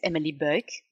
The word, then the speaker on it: Emely Buyck: My name is Emely Buyck.